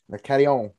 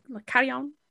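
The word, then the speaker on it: Le Carillon: At la, how do you, how do you say it? La Carillon. – La Carillon. – La Carillon.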